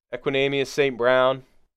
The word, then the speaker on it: Equanimeous St. Brown: Chase Claypool, uh, uh, Equanimeous St. Brown.